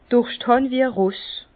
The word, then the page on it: Durscht hàn wie e Ross: Bas Rhin